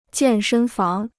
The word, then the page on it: 健身房: jiàn shēn fáng